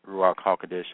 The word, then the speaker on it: Ruach HaKodesh: That's how you're going to stimulate the Ruach HaKodesh, or the Holy Spirit, and that's Ruach HaKodesh, that's in Hebrew, the Holy Spirit. That's how you pronounce it.